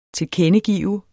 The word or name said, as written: tilkendegive